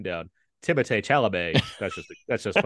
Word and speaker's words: Timothée Chalamet: featuring Taylor Russell and Timothée Chalamet. I think I said that right. – Let's say it that way. – Timothée Chalamet? – Yeah, I guess that's right.